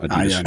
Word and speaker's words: Adidas: It's Adidas, by the way, not Adidas. – Adidas. – Adidas. And, I never heard that. – A Nike. – Americans say Adidas.